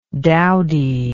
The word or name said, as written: dowdyism